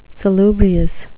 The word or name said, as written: salubrious